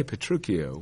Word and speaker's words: Petruchio: The Italian rules for pronouncing that C-H-I-O would tell us that it's Chio. And so when we say Petruchio, we're reconstructing what we take to be Shakespeare's good grasp of Italian spelling. However, the word, the Italian name, is ch. It's spelled differently, but it's exactly.